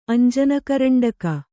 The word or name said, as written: ಅಂಜನಕರಂಡಕ